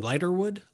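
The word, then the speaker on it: Ligertwood: How do you say her last name? Lighterwood. Lighterwood.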